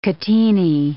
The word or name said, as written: catenae